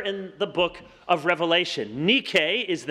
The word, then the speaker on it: Nike: The word is Nike, or in the Greek, you would pronounce it Nike.